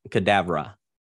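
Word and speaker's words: Kedavra: Aveda. I believe is how it's pronounced. Aveda cadaver. Cadavra. – Cadavra. Okay. I thought it was cadabra. Like abracadabra, but you put cadaver in there?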